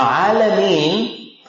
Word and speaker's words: Alameen: Now Alameen, Alameen is not everything that exists. Unfortunately that's what it's translated. It rhymes with Muslimin, Mu'mineen, Salihin, right? Alameen, you see?